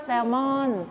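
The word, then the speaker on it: salmon: แซลมอนแต่ภาษาอังกฤษอ่านว่าแซลมอนแซลมอนไม่ออกเสียง L นะ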